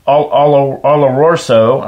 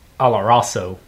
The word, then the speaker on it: oloroso: The finish is long and enticing with the sweetness of dried fruit and the subtle dryness of the oloroso. I cannot say that word. I'm sorry. Nuttiness. How, how would you how would you pronounce that? Oloroso. – Oloroso.